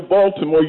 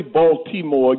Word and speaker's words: Baltimore: But if you're going to come to Baltimore, you don't say Baltimore, you say Baltimore. The T is silent.